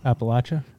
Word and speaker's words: Appalachia: If you're from there, you call it Appalachia. – Yeah. – But if you're from here, you call it, what'd you say? – How'd Appalachian.